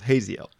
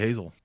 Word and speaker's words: Haziel: I'm going to go with Haziel. – Could just be Hazel. – No, it's, you got to always pronounce the E-L.